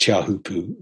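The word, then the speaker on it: Teahupo'o: And if you ever want to watch really, really terrifying surf footage, you should search Chahupu, which is spelt T-Hupu, in Tahiti and watch some of the surf competitions there because it is insane.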